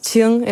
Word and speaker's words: Qing: Dat is Qing in het Mandarijn. En daar stond dan, ja, door heel veel leraren wordt het uitgesproken als King, want die weten niet wat ze ermee moeten.